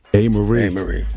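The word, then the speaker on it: Amerie: One Thing, Amari. Somebody told me I said the name. I say her name wrong, but. – A. Marie.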